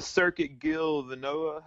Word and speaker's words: Circuit Gilles Villeneuve: That's the Circuit Gilles Villeneuve.